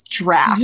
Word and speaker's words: draught: Do you know how many messages I got being like, it's draft?